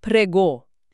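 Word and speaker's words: prego: آره، حرف جی تو این کلمه نباید خیلی سفت تلفظ بشه. صدای نرم شبیه به آوای ژ تو زبان انگلیسی کلمه پلژر یا همون ژ خودمون میده. و حرف اوی پایانی هم باید کاملا کشیده تلفظ بشه. پرگو.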